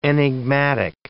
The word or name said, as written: enigmatic